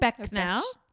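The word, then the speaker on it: Becnel: Okay, so the assistant house manager, Denise Becknell. Oh, I kind of love that name. I don't know if I'm saying it right. It's B-E-C-N-E-L. Becknell? – Becknell? Becknell? Yeah. Yeah, that sounds right.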